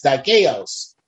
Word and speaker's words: dikaios: The adjective dikaios, dikaios, D-I-K-A-I-O-S, dikaios. I believe that's the pronunciation, dikaios, pertaining to being in accordance with what?